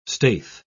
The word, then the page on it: staithe: British English